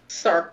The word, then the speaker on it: Sark: Sark rhymes with narc.